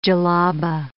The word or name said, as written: djellaba